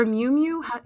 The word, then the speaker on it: Miu Miu: And I think her stepdaughter too was wearing Muay Muay. – Yeah. – And I was just like, or Miu Miu, is that how you pronounce yeah.